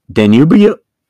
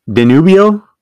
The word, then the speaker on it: Danubio: My my wife who speaks Spanish, uh, Danubio, Danubio, Danubio, Danubio.